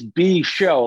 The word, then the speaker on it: B-Show: but it's B-Show, like as in like B, the letter B and then show.